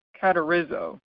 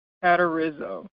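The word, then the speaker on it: katartizo: This Greek word here, right, be made complete, be perfect, is the Greek word katerizo. Katerizo. It is spelled K-A-T-A-R-T-I-Z, as in zebra, O. K-A-T-A-R-T-I-Z-O, katerizo.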